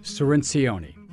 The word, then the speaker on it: Cirincione: Almost. Cirincione. There's a lot more syllables. Not even close. – Not more syllables than you think. But you got the C right.